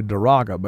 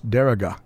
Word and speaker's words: Daraga: And, of course, a fine family and pastors, Anchor Baptist Church, there in, I always want to say Daraga, but that's not the way you say it. It's Daraga, I think, is how you say it, which is right outside of Legazpi City, Philippines.